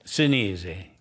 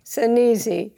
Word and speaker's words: Sinise: And changed it from, how would you say it in Italian? – Sinise. – Sinise.